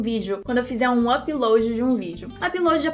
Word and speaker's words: upload: Só que a forma correta mesmo de pronunciar é upload. Você viu que o up fica igual, mas o load fica diferente do que load. Pratica comigo. Upload.